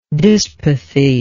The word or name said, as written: dyspathy